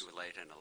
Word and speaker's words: Tualatin: And I guess it seems to me, I'm not sure how to pronounce it, Tualatin, yes. – Tualatin Electric, suggests pretty strongly that it is an issue that is best deferred to the completion of the compliance proceedings.